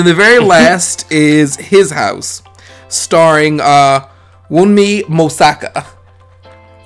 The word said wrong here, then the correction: Wunmi Mosaku: Uh, I want to make sure I'm saying it right. I think you. Wunmi Mosaku. I think that's what it is. – Wunmi Mosaku.